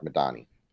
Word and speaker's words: Medhane: Madani, I'm trying to find it. Yeah. – Yeah, it's spelled Medhane. And, you know, but it's pronounced like Madani.